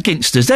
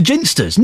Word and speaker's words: Ginsters: I've always said Ginsters. They're telling me it's a soft G. It's a Ginsters. No! No, no, no, no. It's a hard, it's a G. I'm sure of it.